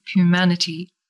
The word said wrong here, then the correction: Numanity: So it's Numanity. – Numanity. – Yes.